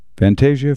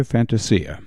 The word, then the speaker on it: Fantasia: I once had a listener call in and tell me the only time that Fantasia should be pronounced Fantasia is in relation to Disney. I'm not sure about that. I've heard it both ways, but take your pick.